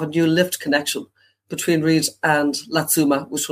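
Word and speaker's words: Riddes: So I flew to Geneva Airport and I took the train direct, switched at Martigny and took another train on to Reed. I think that's the correct pronunciation. It's R-I-D-D-E-S.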